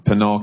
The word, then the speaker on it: Pinal: Sheriff Teeple is from Pinal County.